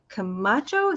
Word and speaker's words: Camacho: And that was, tell us, this is about Anna Camacho. Is that how you say her name? – Yeah, Anna.